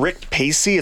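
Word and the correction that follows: Rick Pracey: It's Rick Pracy. There's an R in there. Apologies on that front. I did know that. I was mispronouncing that.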